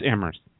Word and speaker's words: Amherst: It's Amherst.